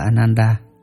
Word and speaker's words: Ananda: Ngài Ananda, Ấn Độ đọc là Ananda, chữ Hán đọc là Khánh Hỷ, là em con chú của Phật, lại được làm thị giả đứng hầu Phật.